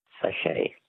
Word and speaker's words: sachet: Words that end with a silent T. Which word for a small sealed pouch literally means little sack in French? – Sachet. – A sachet. S-A-C-H-E-T.